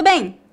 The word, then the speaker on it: bem: Nós pronunciamos a letra M nas palavras bem e bom? Não exatamente. A letra M nas palavras bem e bom tem a função de apenas nasalizar o som das vogais. A letra M não é pronunciada claramente como um M.